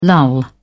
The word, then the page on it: lull: British English